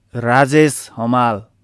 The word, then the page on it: राजेश हमाल: raˈdzes ɦʌˈmal